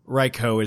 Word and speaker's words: Raikou: Raikou is the official pronunciation.